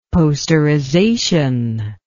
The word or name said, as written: posterization